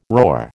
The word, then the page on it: roar: American English